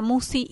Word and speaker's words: Musci: El canal lleva mi nombre, Marisa Musi, y la lista de reproducción es Serenamente. – ¿Cómo se escribe Musi? – M-U-S-C-I.